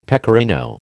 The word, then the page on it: pecorino: American English